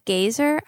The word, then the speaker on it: Gaiser: So that chemist named Conrad Gaiser, I believe is how you pronounce it. It's G-A-I-S-E-R.